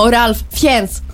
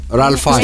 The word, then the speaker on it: Fiennes: Έτσι διαβάζεται ο Ralph Fiennes. – Ralph Fiennes. – Στο δικό μου. Στο δικό μου το μυαλό είναι κάπου Ralph Fiennes. – Για μένα είναι Ralph Fiennes πάντως. – Fiennes. Τι Fiennes, μωρέ. – Fiennes. – Fiennes είναι με F-I-N-E-S. – F-E-I-N-S.